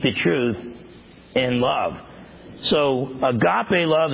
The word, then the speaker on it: agape: There's agape love. A-G-A-P-E is the way we would spell it.